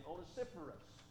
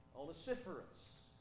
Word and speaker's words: Onesiphorus: There's a statement in here about a man, we preached on him some time back, named Onesiphorus. Onesiphorus.